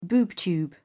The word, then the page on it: boob tube: British English